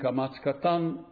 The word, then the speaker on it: kamatz katan: There is a kamatz gadol and there is a kamatz katan. Kamatz gadol is being pronounced, let's say, in Israeli pronunciation as A. Kamatz katan is O.